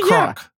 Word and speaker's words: Bachrach: And I'm going to butcher this one. – I got but you. – Ebon Moss Bachrach. – Yeah, actually, Bachrach.